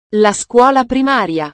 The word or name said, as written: la scuola primaria